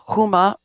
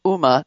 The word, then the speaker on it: Huma: Dr. Bonnie wrote a book called Adultery, The Forgivable Sin, so that may come into this, because Anthony Weiner's wife, her name is pronounced Huma or Uma, depending on who you talk to, has said that she is standing by her man.